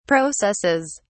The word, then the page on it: processes: British English